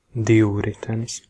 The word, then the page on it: bicyclette: France; [bi.si.klɛt]